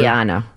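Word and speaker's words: Lijana: Two siblings from the famed Flying Willendas, Nick and Liliana Willenda, safely crossed Times Square. It's Liana. – I like Liliana. It's much more exotic. – That's how she pronounces it.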